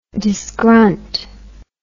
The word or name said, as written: disgrunt